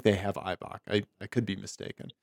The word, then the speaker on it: Eibach: And then here's the Konies, the stock Eibach, I think. Is that how you say it? Eibach? – Mm-hmm. Yeah. Eibach. Yeah.